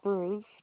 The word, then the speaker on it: Bruce: Alicia is spelled A-L-E-C-I-A. Last name Bruce, B-R-U-C-E.